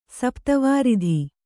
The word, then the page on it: ಸಪ್ತವಾರಿಧಿ: Kannada